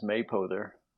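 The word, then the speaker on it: Mapother: The pronunciation is Maypother.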